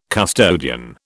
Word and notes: custodian: British English

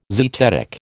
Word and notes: zetetic: American English

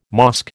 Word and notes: mosque: American English

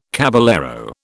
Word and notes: caballero: British English

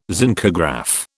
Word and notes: zincograph: British English

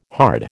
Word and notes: hard: American English

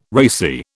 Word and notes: racy: British English

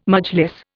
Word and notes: Majlis: Indian

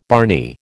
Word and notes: barney: American English

